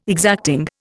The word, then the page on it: exacting: Indian